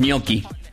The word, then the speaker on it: gnocchi: Que la pronunciación de gnocchi en italiano es gnocchi.